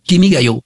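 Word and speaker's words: Kimigayo: Alors, le titre, c'est, bon, je veux dire, comme Chantal tout à l'heure, excusez-moi, Kimi Gayo, ou le titre, Votre règne. – Kimi Gayo.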